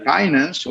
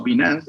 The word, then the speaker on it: Binance: Incluso con los exchanges, o centros de intercambio, están los centros de intercambio centralizados, como puede ser Coinbase, como puede ser eh, Binance o Binance, dependiendo de cómo quieras pronunciarlo, como puede ser el famoso unicornio latinoamericano Bitso, que son monstruos de, del intercambio de activos digitales, pero al final de cuentas centralizados.